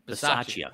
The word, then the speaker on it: Bisaccia: I don't want to butcher his last name, Rich Bisaccia. – Bisaccia.